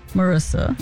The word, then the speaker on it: Marissa: I'm now Marissa the Magic to be aligned with my name. Makes sense, right? – All right. Spell it. – Marissa. M-A-R-I-S-S-A. D-A. Magic. M-A-G-I-C.